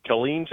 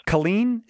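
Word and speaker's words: Killeen: It's actually pronounced Colleen, Texas. – I appreciate it. You don't want to mispronounce a Texas word. Those people will hurt you. I mean, I'm sure you're not mean, but they are in general mean. – No. I'm not from around here. I am Texan, though, so, you know, I gotta. – Wait, so tell me again. It's my Texans. – Colleen is how you say it? – Yes, sir.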